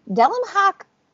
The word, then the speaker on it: Delam Hack: That is officially how you pronounce it. Delam Hack revealed themselves to us.